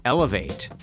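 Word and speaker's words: Elev8: Elevate. Now, it's spelled E-L-E-V and the number 8.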